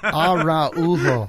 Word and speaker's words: Araujo: You have like a 6-letter last name, and it's 4 syllables long. – Right. – Araujo. It's Araujo. Yeah.